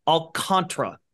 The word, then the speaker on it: Alcantara: And he's got, you know, 3 syllables there. So you get that first one right. Alcantara. Thank you.